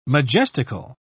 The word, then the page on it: majestical: British English